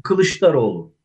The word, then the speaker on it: Kılıçdaroğlu: Kılıçdaroğlu, is that how you say his name? – Yes. Kılıçdaroğlu.